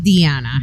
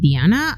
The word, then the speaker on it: Diana: Actually, it's pronounced Diana. – Diana. I apologize. See, I've already screwed it up. – No, it's okay. Okay. It's spelled like Diana, but I'm Mexican, so I pronounce it Diana. I don't want to Americanize my name.